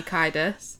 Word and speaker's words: Kiedis: He used to be in the Mild Green Chilies, and he was Anthony Kiedis. Did I say that right? – I think. – Yep.